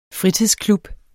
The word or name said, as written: fritidsklub